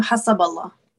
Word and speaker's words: Hassaballa: how do I pronounce your name? Um, Hassaballa. Hezbollah. – Hezbollah. Yeah. – Hezbollah. If you're gonna say it like, yeah. Say that again. I mean, you could do Hezbollah, Hezbollah, but not just stay away from Hezbollah.